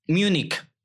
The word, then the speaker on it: Múnich: ¿Cómo es que se pronuncia? Múnich. Ok, Múnich.